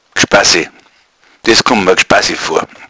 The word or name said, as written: gschpasse